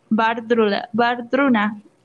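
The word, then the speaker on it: Wardruna: Se llama Bardruna, pero se escribe como Guardruna, que habla sobre temas de nórdicos, o sea, de la religión, de la, pues religión nórdica, podría decir.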